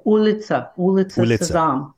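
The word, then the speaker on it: Ulitsa Sezam (Улица Сезам): And then in 93, you got the call from Sesame Workshop to make, am I saying it right? Ulitsa Sezam? – Yeah, Ulitsa Sezam, which just means Sesame Street in Russia.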